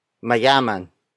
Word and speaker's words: mayaman: Our last example is mayaman. Mayaman. Mayaman. Mayaman is spelled as M-A-Y-A-M-A-N.